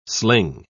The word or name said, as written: sling